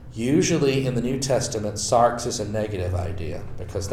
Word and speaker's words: sarx: How do you spell it? – S-A-R-X. – Sarx. – In English. As opposed to soma, S-O-M-A.